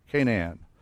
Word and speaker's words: Canaan: And Ham is the father of Canaan. We always call it Canaan, but it's actually a three-syllable word.